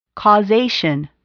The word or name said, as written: causation